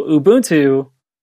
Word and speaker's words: Ubuntu: So I looked it up, and it's Ubuntu. So Ubuntu is African for,